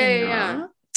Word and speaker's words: Sanaa: Oh, I gotta go to Sanaa. Is that how you say it? – Oh, yeah, Sanaa. Yeah.